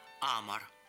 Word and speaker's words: Амор: и рукопись романа Анастасии Цветаевой «Амор». Отмечу, что ударение в этом латинском слове, обозначающем любовь, ставила сама писательница на первый слог «Амор».